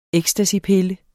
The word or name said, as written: ecstasypille